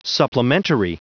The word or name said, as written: supplementary